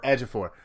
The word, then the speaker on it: Ejiofor: How do you say it? Towie Ekafor, I don't know how to say his name. – Chiwetel Ejiofor.